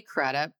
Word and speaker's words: Crudup: Billy Crudup, is that how you say his name? – Yeah, I like him a lot.